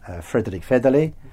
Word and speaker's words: Fredrik Federley: And what we have next Tuesday in the Industry Committee is the first look at the draft opinion by the Swedish Liberal Fredrik Federley. – Absolutely fantastic pronunciation.